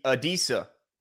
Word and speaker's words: Adisa: Is it Adasi? Adisi? – Adisa. – Adisa. Like Adisa Isaac.